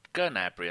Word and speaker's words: Gnabry: Oh, Gnabry. I think someone told me you do pronounce the G in German when you say it in German. – Yeah, you do.